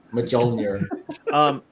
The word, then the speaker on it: Mjolnir: A lot of it's his humor, but obviously he's got some great qualities because he can lift, you know, Mjolnir. Did I say that right, Kevin? – I think so. – Close enough. – Yeah. – Okay. Silent J. – Silent J. – Mjolnir. – Mjolnir.